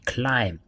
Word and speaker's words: climb: 比如说，climb 攀登，c l i m b climb，哎，b 就没有发音，那不是 climb。